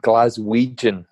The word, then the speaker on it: Glaswegian: So we know that you grew up a glass wedging. The most important question is, did you grow up a hoop supporter? – Yeah, that's a good question. Glaswegian. There we go. That's the pronunciation of that.